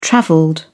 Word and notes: travelled: British English

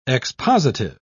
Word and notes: expositive: British English

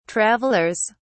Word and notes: travelers: British English

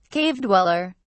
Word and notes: cave dweller: British English

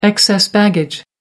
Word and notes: excess baggage: American English

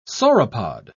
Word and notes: sauropod: British English

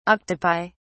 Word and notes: octopi: British English

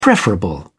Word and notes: preferable: British English

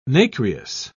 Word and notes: nacreous: British English

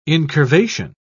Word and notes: incurvation: British English

